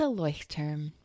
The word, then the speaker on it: Leuchtturm: It's a Leuchtturm. – Leuchtturm.